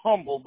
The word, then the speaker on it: Humble: Pronounce it Humble, but but the H is silent.